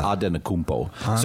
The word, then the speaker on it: Antetokounmpo: So, because it's not a Greek name, it's a Nigerian name. It's Antetokounmpo.